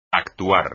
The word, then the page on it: actuar: espagnol